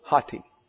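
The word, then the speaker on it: hati (ὅτι): And so that would be O-T-I, ati. But this has a rough breathing mark in front of it, and that makes it have an H in front. So, hati is the correct pronunciation.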